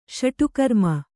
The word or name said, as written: ಷಟ್ಕರ್ಮಿ